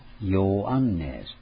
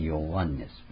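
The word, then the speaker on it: ヨ ア ン ネ ス (Ἰωάννης): ヨハネっていうのはギリシャ語の発音、新約聖書はギリシャ語ですから、ギリシャ語では長い音が多いんですね。ヨアンネスって言われて、ヨアンネス。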